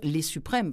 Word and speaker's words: Les Suprêmes: Est-ce qu'il faut, le dire, Les Suprêmes, parce qu'on ne le prononce pas à ce moment-là à l'anglais.